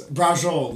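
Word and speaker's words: braciole: It's not prosciutini, but it's brajol. There you go.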